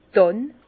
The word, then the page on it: d' Dànn: Alsatian; Bas-Rhin; Herrlisheim